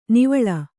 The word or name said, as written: ನಿವ್ವಳ